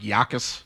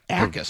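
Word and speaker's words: Jacas: Yeah, the silent J. – It's kind of like jackass, but it's no S, one S, and it's an A. – Akis. I would have guessed, I think at first I was saying like Yakis. – Yeah, like with a Y. – Yeah, but it's Akis.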